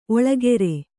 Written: ಓಲೆಕಾರ

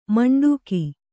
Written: ಮಂಡಗೆ

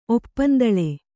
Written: ಒಪ್ಪಂದಳೆ

